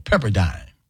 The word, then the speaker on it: Pepperdine: Long Eye? Dine? Okay. Rhymes with fine and line. Okay, Pepperdine.